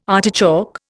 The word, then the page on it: artichoke: Indian